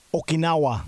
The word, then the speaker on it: Okinawa: Okinawa. There, it's close. There it's close. You just got to say it quicker.